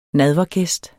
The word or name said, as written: nadvergæst